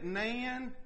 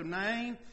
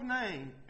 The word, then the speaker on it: Nain: I've always said it Nan, but it's spelled like rain, so Nain. It's the widow of Nain, and when Christ resurrects her son.